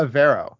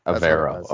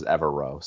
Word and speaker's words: Evero: I was Googling something, because I can never pronounce the defensive coordinator's name for the Broncos. Ejiro Avero. – Avero. Okay. Yeah. I thought it was Evero.